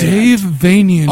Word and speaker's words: Dave Vanian: Dave Vanian, pronounces Transylvanian.